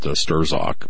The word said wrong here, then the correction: Strzok: but apparently the Z is silent.